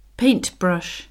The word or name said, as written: paintbrush